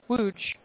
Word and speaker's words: Łódź: L-O-D-Z.